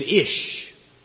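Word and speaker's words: ish (אִישׁ): Now in the Hebrew language in which the Old Testament is written, the word for man is ish. And we would spell it I-S-H.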